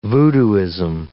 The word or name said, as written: voodooism